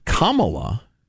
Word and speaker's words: Kamala: A friend of mine pointed out it's pronounced Kamala, and you remember because she's a communist.